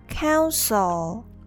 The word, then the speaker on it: counsel: And the second one, counsel, counsel. The E in the second syllable pronounced as the schwa sound, uh, sol, sol, counsel, counsel.